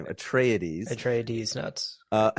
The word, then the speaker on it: Atreides: Atreides.